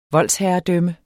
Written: voldsherredømme

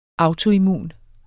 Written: autoimmun

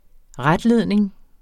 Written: retledning

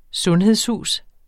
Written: sundhedshus

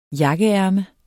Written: jakkeærme

